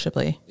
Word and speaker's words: Chablis: It's a region in France, so it's probably pronounced Chablis. – Yeah, I think you're right. I think it's Chablis. – Yeah, it's Chablis. Okay.